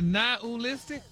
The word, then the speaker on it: nihilistic: But you actually pronounced it correctly the last time you said it. – Really? Nihilistic? – Wow. – Stop putting the ooh in there.